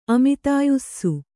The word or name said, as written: ಅಮಿತಾಯುಸ್ಸು